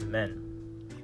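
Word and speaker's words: men (麺): So the Japanese word for noodles is men. Men. So it sounds like the word men, as in the plural for man.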